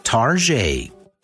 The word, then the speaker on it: Target: Retailer Target has a long standing nickname, Target. That pseudo French pronunciation